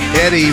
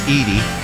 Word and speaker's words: Eddie: Eddie Rabbit. Unless it's Edie. Is it E.D. Rabbit? – No, it's Edie two Rabbit. D's, so it means it's a short E sound.